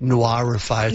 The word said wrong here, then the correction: Norify: It's much harder to say. – Yeah, so just Norify.